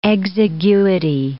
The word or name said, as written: exiguity